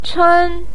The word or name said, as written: chen1